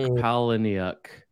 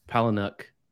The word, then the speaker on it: Palahniuk: So I can never pronounce his name, Chuck Palahniuk. – Oh, that's better than I've ever done it. So yeah, Palahniuk.